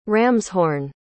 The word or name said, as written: ramshorn